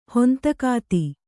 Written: ಹೊಂತಕಾತಿ

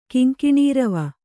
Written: ಕಿಂಕಿಣೀರವ

